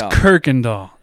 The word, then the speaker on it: Kuykendall: Kuykendall. It's Kuykendall. That's how you say it. – There is definitely not an R.